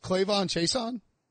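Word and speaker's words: K'Lavon Chaisson: Wait, so it actually rhymes, Clavon Chason? – Yeah, that's easy.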